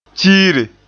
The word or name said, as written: tiire